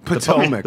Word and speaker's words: Potomac: And also, just so I can check, so I don't get this wrong in the future, it's pronounced Potomac, right? – Potomac.